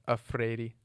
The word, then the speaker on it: Freire: Yeah, in English, yeah. – What's it in? – In Portuguese, it's more of like a Freire.